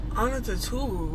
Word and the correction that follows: Antetokounmpo: what? – That's not his last name? – It's Antetokounmpo.